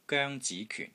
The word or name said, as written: GOENG1 ZI2 KYUN4